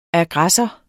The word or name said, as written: aggressor